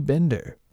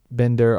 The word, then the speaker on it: Binder: And I just fell in with this Timothy Bender. I've heard it pronounced both ways, Bender and Binder. I noticed Lauren said Binder in the synopsis, but whatever is here or there, it's really not that important.